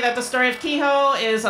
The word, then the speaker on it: Queho: So also one thing, most people pronounce his name Queho, but the Spanish pronunciation, because his name is derived from the word quejar, which means to complain. He was a complainer. So we're going to call him Queho.